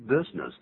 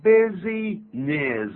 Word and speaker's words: business: 所以在这个地方特别我们叫了一个 on business，出差。谈的这个 business 本来是生意啊，但念的时候千万不要念成 business。 – 不对。 – 这样念是不对的，应该怎么念呢？ – Business. Business.